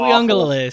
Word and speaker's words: Uiagalelei: DJ Uiagalelei, or however you say his name, has been awful. – Uiagalelei, say it right.